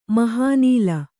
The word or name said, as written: ಮಹಾನಿಲ